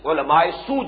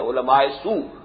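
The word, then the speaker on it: علماء سوء: احبار حبر کی جمع ہے, حبر کہتے ہیں بہت بڑا عالم, لیکن سو کا لفظ ہے, یعنی علماء سو جنہیں ہم کہتے ہیں, عام ہماری غلط العام عام جو تلفظ ہے علماء سو, لیکن یہ کہ صحیح تلفظ علماء سو ہے.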